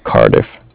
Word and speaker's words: Cardiff: It's C-A-R, like car, and then D-I-F-F, diff, like different, Cardiff. – Okay, the Bank of Cardiff? – Yeah.